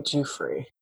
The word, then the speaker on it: Giuffre: Also, like one of the women who claims Jeffrey Epstein sex trafficked her, Virginia Giuffre. Is that how you pronounce it? Something like that. – I was listening to that article. – Yeah. – And they kept saying Giuffre.